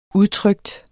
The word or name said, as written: udtrykt